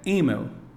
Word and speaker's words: email: A pronúncia correta é chat. Mais uma vez, chat. Ou, de repente, sobre correspondência eletrônica. A pronúncia correta é email.